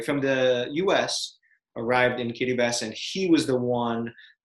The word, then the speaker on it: Kiribati: Now it is pronounced Kiribati. The T-I, or that T in the T-I is actually pronounced like an S.